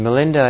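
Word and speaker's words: Milinda: And Melinda is the Pali pronunciation of Meninder.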